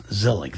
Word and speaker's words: Zelig: Yes, Zelig. That's spelled like Woody Allen Zelig.